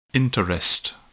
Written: interest

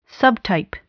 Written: subtype

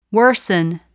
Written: worsen

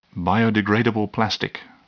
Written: biodegradable plastic

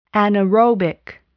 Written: anaerobic

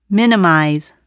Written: minimize

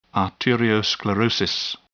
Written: arteriosclerosis

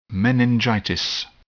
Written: meningitis

